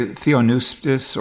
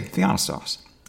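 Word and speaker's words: theopneustos: A lot of people say Theonustos or Theopistos or something of that nature. But you know, from I went to several sources to say, okay, this seems to be the right way to say Theonostos.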